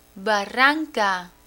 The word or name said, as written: barranca